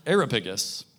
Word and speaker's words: Areopagus: So Paul, standing in the midst of the Areopagus, don't want to say that wrong, Areopagus, said, "Men of Athens, I perceive that in every way you are very religious."